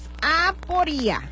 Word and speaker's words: aporía: Así es, aporía. Acento en la I, aporía.